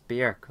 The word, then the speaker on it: Björk: But I remember in an interview she said it's Björk, like jerk, which is really funny. – It's spelled B-J-O-R-K.